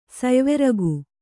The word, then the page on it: ಸಯ್ವೆರಗು: sayveragu